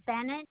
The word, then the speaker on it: Bennett: It's P-R-I, S like in Sam, C like in Charlie, I-L-L-A. – And your last name? – Is Bennett, B-E-N-N-E-T-T.